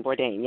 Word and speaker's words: Bourdain: Anthony Bourdain. – Bourdain, yes.